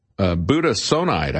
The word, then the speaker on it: budesonide: And so the asthma drug was budesonide, I guess is the way you pronounce that. B-U-D-E-S-O-N-I-D-E, budesonide, a drug that is commonly used to treat asthma.